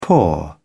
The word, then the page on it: poor: British English